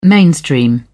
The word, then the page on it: mainstream: British English